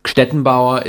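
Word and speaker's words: Gstettenbauer: Ja, das ist, es ist wirklich so, es ist ein G am Anfang, GST, und man, Stettenbauer ist das so, und ich hätte niemals gedacht, dass der Name sich tatsächlich so durchsetzt.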